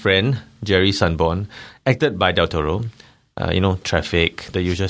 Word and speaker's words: Del Toro: Del Toro.